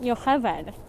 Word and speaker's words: Yocheved: Oh, in Hebrew, that would be pronounced Yocheved.